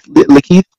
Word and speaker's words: LaKeith: If, am I saying it wrong? Lakeith, Lakeith, it could be said either way. It sounds right to me. Lakeith Stanfield, right? Lakeith. Lakeith. Yeah, Lakeith. I was I was stressing both. I was stressing that first syllable.